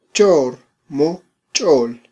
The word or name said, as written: chormochol